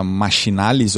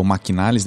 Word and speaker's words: Machinalis: O Mercado Livre comprou recentemente uma empresa chamada Machinalis ou Maquinalis, dependendo de se você está pronunciando o CH como CH ou como Q.